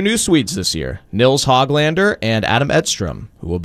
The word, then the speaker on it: Höglander: Nils Höglander has told us he wants us to pronounce his name Nils Höglander.